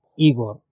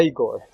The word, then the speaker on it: Igor: Ah, it's Igor. – Igor, como ojo, ojo, gor. Igor.